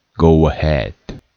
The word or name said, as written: go ahead